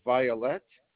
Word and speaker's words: Violette: The last name is Violette.